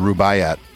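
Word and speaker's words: Rubáiyát: How do you pronounce this? Rubaiyat? – I would say it was called Rubaiyat.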